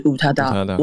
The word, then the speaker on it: 宇多田: 那个宇多田的日文发音是 Utada，我可不可以选择我的称谓是 Mystery 神秘？ – 对，神秘。然后他也自己手写了一个 M Y S dot Utada 在他的 Instagram 的图片上面。